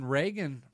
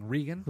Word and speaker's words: Regan: I think the Brian Regan. I keep saying that wrong. Regan.